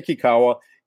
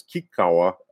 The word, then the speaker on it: Kikawa: Yeah, I say Kikawa. If it's a proper Japanese pronunciation, it's Kikawa, but nobody says that here. Only in Japan am I called that, and I'm not in Japan.